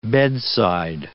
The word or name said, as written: bedside